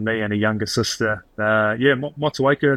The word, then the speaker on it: Motueka: It's Motueka? – Yeah, Motueka.